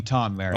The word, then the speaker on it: Tom Merritt: I prefer if you call me Tom Merritt. – Oh, shoot. – Oh, Tom Merritt. – I should have checked before I started. – Mm-hmm. – We've been pronouncing it the wrong way this whole time.